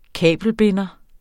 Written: kabelbinder

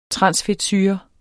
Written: transfedtsyre

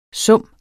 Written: summen

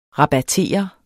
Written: rabattere

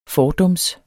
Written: fordums

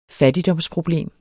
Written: fattigdomsproblem

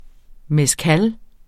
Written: mezcal

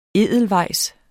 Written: Edelweissen

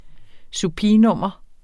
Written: supinum

